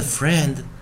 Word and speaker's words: friend: 啊，第一个元音跟最后一个 approximate r 结合做双元，t t t，上面的 friend 啊，他要记得老师讲单独记忆啊，前面的 i 不发音，后面的 e 念短元，and and friend friend friend，好，所以在这一个循环中哦，我们可以到同样是 i e 做一个结合的时候。